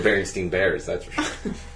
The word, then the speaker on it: Berenstain: It's got to be Berenstain because there has to be a bear at the beginning.